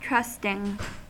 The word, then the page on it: trusting: American English